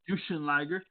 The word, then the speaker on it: Jushin Liger: Now, for last year's Hall of Famers, we got JBL, we got Justin Liger. I believe that's how I pronounce it. – Jushin Thunder Liger. – Jushin Liger.